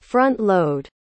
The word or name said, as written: front-load